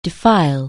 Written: defile

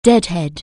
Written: deadhead

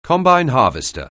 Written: combine harvester

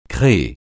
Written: créer

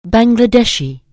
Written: Bangladeshi

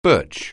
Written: birch

